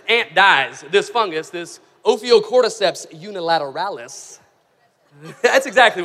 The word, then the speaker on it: Ophiocordyceps unilateralis: There's a certain kind of ant that when it dies, there's a certain fungus known as Ophiocordyceps unilateralis. – Oh, that sounded right. – You nailed it. – Ophiocordyceps unilateralis.